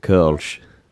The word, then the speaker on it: Kölsch: It's the umlaut above the O. – Yes. Yeah. That I believe is, you pronounce it Kölsch.